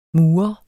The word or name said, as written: murer